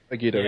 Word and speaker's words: Agito: And back when it was Final Fantasy, I don't know how it's pronounced, I guess, Agito? – Agito, yeah.